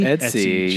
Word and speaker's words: Etsy: I think it's E-T-S-Y. – No, how you pronounce it. Because it's like, it's E-B, so it's eBay, but it's like Etsy.